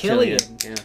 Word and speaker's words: Cillian: Is that how you pronounce it? I thought it was Cillian. – Me too at first, but it's Cillian. Yeah.